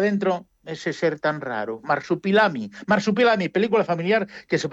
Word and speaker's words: Marsupilami: Pues para todo el mundo, Marsupilami, que no sé si lo he dicho bien, sí, ¿verdad? – En efecto. Uh-huh. Película, sí, sí, lo he dicho perfectamente, Marsupilami.